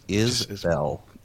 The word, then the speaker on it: IsBell: That's it. Just two syllables. IsBell.